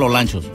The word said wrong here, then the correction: Olancho: No, Olancho, de donde, según dicen, entran muchos y salen pocos.